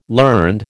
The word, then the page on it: learned: American English